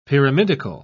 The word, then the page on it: pyramidical: British English